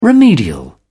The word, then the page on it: remedial: British English